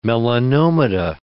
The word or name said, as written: melanomata